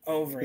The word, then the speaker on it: Overeem: Overeem is how you say his name.